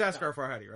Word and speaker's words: Asghar Farhadi: It's Asghar Farhadi, right? – Yes.